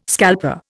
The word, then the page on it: scalper: Indian